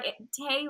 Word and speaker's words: Tay: It is a long story, but my original username came from a song, but Tay rhymes with parade, so that is my username.